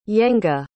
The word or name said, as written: yenge